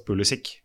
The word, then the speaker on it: Pulisic: For han sa, det var vel han Stamsø-Møller som sa til, nei, han velger jo, han sier jo selv at det skal uttales Pulisic, men ikke vet vi hva vi skal si. Og så fortsetter å si Pulisic.